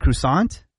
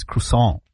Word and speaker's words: croissant: Is it croissant? – Croissant. – Oh. – But that's not the proper pronunciation.